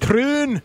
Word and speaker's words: Troon: Troon.